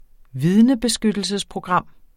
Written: vidnebeskyttelsesprogram

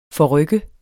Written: forrykke

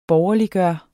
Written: borgerliggøre

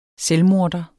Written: selvmorder

